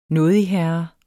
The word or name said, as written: nådigherre